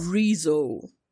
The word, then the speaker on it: Rezo: Rezo.